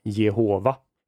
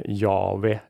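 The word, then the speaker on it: Jahve: Och, och då kan man nog vara ganska säker på att det uttalades inte så som man har trott från är det 15-1600-talet framåt, nämligen Jehova, utan snarare eh, Jave eller någonting eh, sådant.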